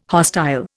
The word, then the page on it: hostile: Indian